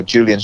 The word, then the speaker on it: Julian: Yeah, yeah, and you know, Julian Shah Tayler is is my full name.